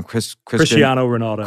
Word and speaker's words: Cristiano Ronaldo: Cristiano Ronaldo.